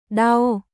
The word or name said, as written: Đau